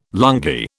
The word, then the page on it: lungi: British English